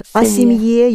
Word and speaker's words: о семье: And о семье, you keep the stress on the ending.